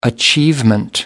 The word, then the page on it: achievement: American English